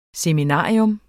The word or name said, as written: seminarium